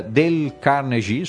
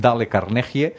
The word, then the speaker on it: Dale Carnegie: És un llibre de Dale Carnegie, suposo que s'hauria de pronunciar així, però tothom el coneix com Dale Carnegie, és a dir, l'art de parlar en públic, l'art de parlar en públic, cosa que respon també a aquest pensament liberal.